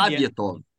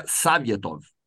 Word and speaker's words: Sovetov: Savietov. E é Savietov. Né? Porque o O em russo, quando não é sílaba tônica, tem som de A.